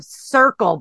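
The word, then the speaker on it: Cerkl: Hey, so you're the CEO and founder of Circle, but it is not pronounced or not spelled Circle. It's C-E-R-K-L.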